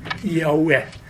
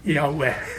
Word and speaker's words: Jahve: og så kom det punktet der han skulle uttale det navnet som har konsonantene JHVH. Og min venn spisset ører, og han hørte bare vokaler. Jaue. Jaue.